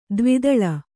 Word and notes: ದೇವದಾಳಿ: dēva dāḷi

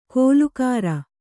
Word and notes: ಕೋಲುಕಾರ: Kannada; kōlukāra

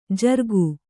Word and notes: ಝುರುಕಿ: jhuruki

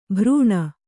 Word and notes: ಭರಣ: Kannada

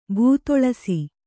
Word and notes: ಭೂತೊಳಸಿ: Kannada

